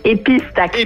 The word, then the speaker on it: épistaxis: Épistaxis. – Épis, bon, on prononce le S. Épistaxis.